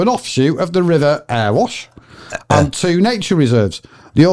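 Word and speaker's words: Erewash: This is Earwash. Earewash. Earwash Council. Earwash Council. – Could you just spell it again? – ERE. – Yeah. – WASH. – Earwash.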